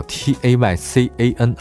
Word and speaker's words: Taycan: 今天说的第一款保时捷啊，这是它的新能源那个名字叫 T A Y C A N 啊，我曾经在节目里教过大家怎么念这个名字叫 Taycan。